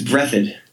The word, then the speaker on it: Breathed: I don't think you're trying to live like the Berkeley Breathe. Is that how it's pronounced? Bloom County's author? I've never quite known. – I think it's Breathed. – Breathed? Okay. Berkeley Breathed.